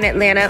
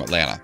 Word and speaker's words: Atlanta: And it says the word Atlanta, and then it's crossed out, and it says Atlanta, A T L A N N A. Apparently, that's how you pronounce it if you're from Atlanta.